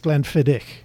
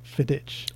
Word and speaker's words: Glenfiddich: But, you know, it's Glenfiddich, not Fidich.